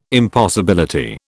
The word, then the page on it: impossibility: British English